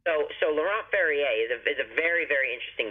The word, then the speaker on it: Laurent Ferrier: Number 99, Laurent Ferrer. – Laurent Ferrier, right. – How would I say it? Laurent? – Laurent Ferrier.